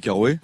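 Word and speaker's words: Carøe: Alors Careux, c'est C-A-R-O-E. – Dans le milieu, tout le monde dit Karoé. – On dit Karoé parce que voilà, c'est un peu compliqué à prononcer à la danoise.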